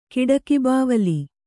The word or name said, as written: ಕಿಡಕಿಬಾವಲಿ